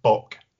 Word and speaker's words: Bak: So the guys who wanted to play football left and formed their own club, BAK, which is pronounced Bok in Hungarian.